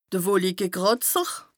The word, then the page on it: de Wolikekràtzer: Bas-Rhin; Strasbourg